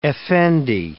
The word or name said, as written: effendi